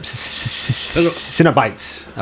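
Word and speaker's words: Cenobites: Cinebites, I believe. It's got that soft C there. It's hard for me to say. Cinebite, I believe.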